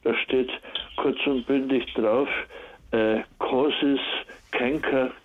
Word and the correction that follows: cancer: oder spricht man Cancer, Cancer aus, also ja.